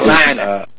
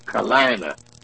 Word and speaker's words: Carolina: Carolina. It's not Carolina. It's Kalina. Come on now. Talk right. It's Kalina. North Carolina. Not Carolina. There's not four syllables in Carolina.